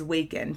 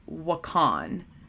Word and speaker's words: Wakan: The best known Dakota word for magic is Waken, pronounced Wakan. It says it's pronounced W-A-H-K-A-H-N. So I think it's Wakan. So I'm so sorry. I keep saying Waken, but it is Wakan.